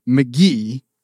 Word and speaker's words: Mk.gee: And McG, the singer, we're told, is pronounced McGee. Even though this man has a project earlier in his career called Pronounced M-C-G-E-E.